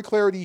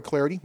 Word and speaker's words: Clardy: Troy Clarity here in my 28th year of following Stanford sports. You can follow me on Twitter at Troy Clarity, last name is spelled C-L-A-R-D-Y, Troy Clarity, last name is C-L-A-R-D-Y, Pac-12 Network play-by-play announcer, and knee-deep in everything right now with everybody playing and everybody going.